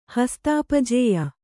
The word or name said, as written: ಹಸ್ತಾಪಜೇಯ